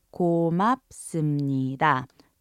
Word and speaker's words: 고맙습니다: Once again, it's pronounced 고맙습니다.